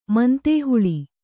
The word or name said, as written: ಮಂತೇಹುಳಿ